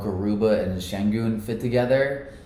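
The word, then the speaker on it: Şengün: Sengun. It's like a SH.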